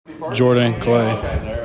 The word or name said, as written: JORDAN CLAY